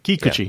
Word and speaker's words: Kikuchi: Emphasis on the syllable, on the first syllable. – Mm-hmm. – Kikuchi.